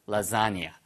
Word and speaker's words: lasagna: To your lasagna. Lasagna. L-A-S-A-G-N-A에서 발음은요. 라자니아처럼 발음돼요.